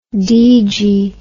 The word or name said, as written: deegy